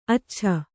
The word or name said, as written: ಅಚ್ಚಚ್ಚೆ